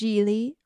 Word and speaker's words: Gigli: Uh, yeah, it's pronounced Geely, rhymes with really.